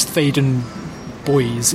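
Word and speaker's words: Theydon Bois: People often mispronounce it as, or don't know whether it's Boys or Bois or Thaden or Thaden. I think it's Thaden Boys is the correct way to do it.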